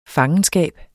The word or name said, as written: fangenskab